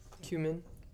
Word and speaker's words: cumin: Cumin. Is that how you say it? Cumin? – Cumin.